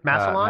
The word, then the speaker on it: Massillon: how do you say this town name again? – Massillon. Uh, Massillon. Oh, Massillon. oh, by the way, apologies to, this, to the residents of Massillon if I'm mispronouncing your okay, name. Okay, well, we're going to run with that pronunciation.